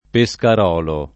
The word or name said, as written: Pescarolo